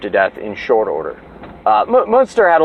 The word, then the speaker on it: Münster: You can look up Münster. It's spelled like monster, but with a U, and I think it's EU. Münster, like Herman Münster.